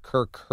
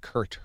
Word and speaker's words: Kirk: He called Kirk Herbstreit Kurt Herbstreit. And now recently, this week, he called Waco, Texas, Waco, Texas.